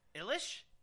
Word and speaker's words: Eilish: It's Billie Eilish? – Spell it. – E-I-L-I-S-H.